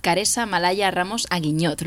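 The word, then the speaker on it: Karessa Malaya Ramos Aguiñot: Estamos hoy muy bien acompañadas de Caresa Malaya Ramos Aguiñot. ¿Lo he dicho bien? – Lo has dicho perfectamente.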